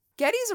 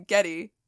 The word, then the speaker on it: Geddy: Geddy's original name was Gary. However, due to his mother's heavy accent, she pronounced his name Geddy. – Geddy. – So it's Geddy Lee, but it's supposed to be Gary Lee.